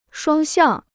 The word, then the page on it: 双向: shuāng xiàng